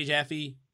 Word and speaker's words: Jaffe: Jaffe.